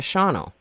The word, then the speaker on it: Shawano: It's, and people put the A, they pronounce the A and they shouldn't. – Right. – Shawano. That's the Shawano instead of Shawano.